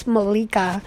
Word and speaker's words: Milika: And they, you know, those that are close to me, my name is Milika, to be like properly pronounced.